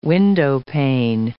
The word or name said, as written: windowpane